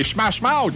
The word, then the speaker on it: Smash Mouth: Un jour, je vais le prononcer comme il faut le nom de ce groupe. C'est Smash Mouth. – Oui, t'es bon.